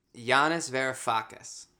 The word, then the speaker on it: Yanis Varoufakis: Yanis Varoufakis.